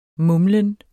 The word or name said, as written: mumlen